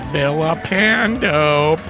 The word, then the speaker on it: Villapando: There's Vincent Villapando, pronounced with the L's making an L sound, not a Y sound.